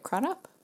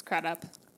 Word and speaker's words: Crudup: Is it Crudup? – It's Crudup.